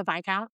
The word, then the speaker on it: Viscount: Viscount. – Oh, okay. – Yeah. – Because it's got an S in it. So in my head, I had Viscount? – Always, like Viscount. Viscount. Yeah.